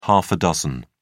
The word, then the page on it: half a dozen: British English